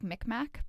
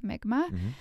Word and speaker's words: Mi'kmaq: It looks like Mi'kmaq, but it's pronounced Mi'kmaq.